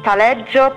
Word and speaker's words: Taleggio: And for the cheeses, Taleggio, Provolone, Pecorino Romano e Toscano, Parmigiano, Mozzarella di Bufala, Grana Padano, Gorgonzola, Fontina and Asiago.